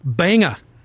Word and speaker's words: Banga: Banga. – Unless they're pronouncing it different. It's B-A-N-G-A. – Like the city in Maine? Banga.